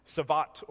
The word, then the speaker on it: Sabbat: In the Bible, the word that we're using for rest, we're going to see it's Sabbath or Sabbat, depending on how you pronounce the Hebrew.